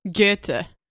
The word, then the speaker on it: Goethe: Goethe.